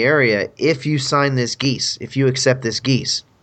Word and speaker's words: geas: And for our listeners, that's spelled G-E-A-S. – Yeah, it's as the D&D spell, Geas Quest. – Not a waterborne fowl.